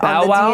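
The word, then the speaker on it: Babau: Babao, I think, is how it's supposed to be pronounced. I listened to Matt Mercer Babao. Say it Bow-wow? – On the D&D Beyond website.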